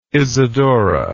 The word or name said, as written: isadora